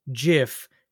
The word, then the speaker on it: GIF: He declared that GIF, spelled G-I-F, is pronounced JIF, J-I-F.